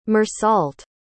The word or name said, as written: meursault